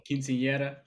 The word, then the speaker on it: quinceañera: there's always quinceañera with the Sweet 15 joints. I know I can't pronounce it right. – Oh, quinceañera. – Quinceañera, there we go.